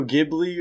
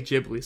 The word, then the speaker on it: Ghibli: But I've just always heard everyone say Ghibli, so I say Ghibli. – That's what I've been doing. I see, how it's supposed to be pronounced in Japanese is J-I-B-U-R-I. Don't know where the R came from.